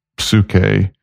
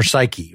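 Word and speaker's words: Suke (ψυχή): Like, there is a standard pronunciation that you learn, Suke.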